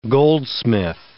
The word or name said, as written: goldsmith